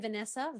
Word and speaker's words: Vanessa: So obviously the name Vanessa, V-A-N-E-S-S-A.